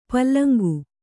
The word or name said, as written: ಪುಲ್ಲಿಂಗ